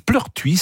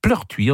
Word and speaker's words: Pleurtuit: Eh bien à Pleurtuy, en Ille-et-Vilaine, Pleurtuy, ça s'écrit P-L-E-U-R-T-U-I-T, Pleurtuy en Ille-et-Vilaine, la mairie nomme le marché de Noël le village enchanté.